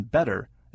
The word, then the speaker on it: better: Just as you don't say the T in listen, the TT in better is pronounced D, better.